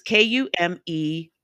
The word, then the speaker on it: Kumeyaay: The name Otay comes from Kumaya. And I know I just pronounced that. I just butchered that. I'm sorry. It's Kumeyaay.